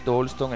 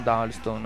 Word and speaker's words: Dalston: La pronuncia scopro ora che è Dalston e non Dalston, no? – Dobbiamo scriverlo un corso d'inglese.